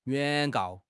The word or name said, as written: 原告